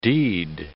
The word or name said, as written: deed